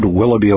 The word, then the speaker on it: Willoughby: And that's spelled W-I-L-L-O-U-G-H-B-Y.